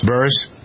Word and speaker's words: Buress: Buress.